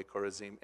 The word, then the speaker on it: Chorazin: And over on the upper corner of the lake, about where Appleton is, that's where Bethsaida and Chorazin, where those cities are, putting the accent on the last syllable because that would be more of a Hebrew way, Chorazin.